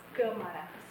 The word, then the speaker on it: Câmara: I have seen his last name pronounced in different ways. Some people say Camara or Camara. It's, in Portuguese it's Câmara, so the closest I think pronunciation would be like that machine that takes pictures, you know, camera.